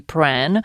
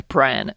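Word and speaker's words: Prahran: Perché chi ascolta, che magari ha letto come si scrive sul podcast, dirà ma come Pran? E invece è proprio così che si dice.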